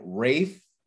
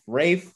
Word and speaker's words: Ralph: There's a trailer for what is being billed as a horror comedy called The Menu, starring Ralph, even though it's spelled Ralph, Ralph Fiennes, Nicholas Hoult, and Anya Taylor-Joy.